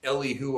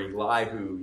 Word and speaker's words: Elihu: and his name is Elihu or Elihu. You can pronounce it either way. There's kind of alternate pronunciations there.